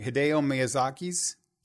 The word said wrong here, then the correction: Hayao Miyazaki: Hayao Miyazaki. – Hayao Miyazaki's. – I mean, I'm still pronouncing it wrong, but H-A-Y-A-O.